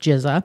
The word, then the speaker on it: GZA: So the Genius became GZA, which is GZA, pronounced GZA.